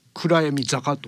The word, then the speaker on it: 暗闇坂: サマーフィルム、ね、暗闇坂で、あのローマ字表記して暗闇坂と読む、